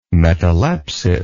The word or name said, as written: metalepsis